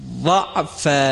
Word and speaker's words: ضعف: ضعف، حرف ضاد هست باید درشت ادا بشه.